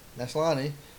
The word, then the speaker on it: Maslany: Maslany, did I say that right? – Sounds right. – Maslany, finally wins after 4 seasons.